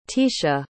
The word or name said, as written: teasha